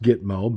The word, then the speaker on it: Gitmo: Your fucking ass ought to be hauled down to Gizmo. – Now, of course, it's actually pronounced Gitmo, but you get the idea, folks.